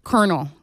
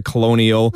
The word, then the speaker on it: colonel: First of all, colonel. When you see, and it's because of the way it's spelled, obviously, C-O-L-O-N-E-L. – My wife, Kelly, she cannot pronounce that word for the life of her.